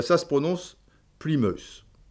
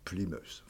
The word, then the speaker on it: Plymouth: Euh, parce que le Y, là, des fois, le Y, c'est Sly, tu vois, S-L-Y, tu vois, c'est, tu vois. Et, et, et Mouth, M-O-U-T-H, tu es dans la merde. Ben, ça se prononce Plymouth.